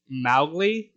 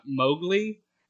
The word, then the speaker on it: Mowgli: Apparently, his name is supposed to be pronounced Mowgli, not Mowgli, and Rudyard Kipling's daughter never forgave Walt Disney for getting that wrong, which is hilarious to me.